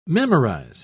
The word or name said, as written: memorize